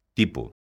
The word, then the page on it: tipo: galega